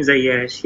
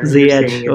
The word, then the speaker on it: Ziyech: Yeah, Hakim Ziyech. Yeah. – Ziyech.